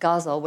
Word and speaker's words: ghazal: It's spelled G-H-A-Z-A-L, but it's really pronounced guzzle, which is kind of a funny word for a form.